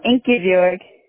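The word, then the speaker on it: Ingibjörg: Oh, Ingibjorg.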